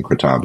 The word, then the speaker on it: kratom: And he's like, "It's kratom," or, or he might have been pronouncing kratom, but it is pronounced kratom.